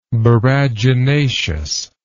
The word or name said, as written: boraginaceous